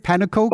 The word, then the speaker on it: pannenkoek: Maybe slightly related to a Stroopwafel because it's round and it's flat is the Dutch pancake called the pannenkoeken. Yeah. – Pannenkoek. – Pannenkoek, is that how you say it? Pannenkoek? – Pannenkoek. – Okay.